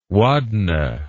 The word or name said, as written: wadna